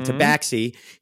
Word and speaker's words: tabaxi: Well, my character is it a tabaxi? Is that how you say it? – Mm-hmm. – Yes, it's a tabaxi.